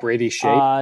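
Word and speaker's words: Skjei: Well, like here, there's Jake Bean, Joel Edmondson, Hayden Fleury, Gardner, Dougie Hamilton, Brent Pesce, Brandon Skaya. I don't know how to pronounce that. – Brady Shea. – Brady Shea, that's it.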